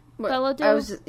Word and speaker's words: Bilodeau: Jean-Luc Belladeau, is that pronounced right? Jean-Luc Belladeau? – Yes. – Yeah.